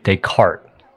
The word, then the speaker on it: Descartes: You said the philosopher was René Descartes. – Yeah. – His name is pronounced René Descartes. He's French. Descartes.